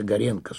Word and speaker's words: Горенко: 11 июня 1889 года, по-старому, 110 лет назад, под Одессой, в семье отставного морского офицера родилась Анна Андреевна Горенко, иногда произносится Горенко с ударением на «е», будущая величайшая поэтесса Анна Ахматова, Анна всея Руси.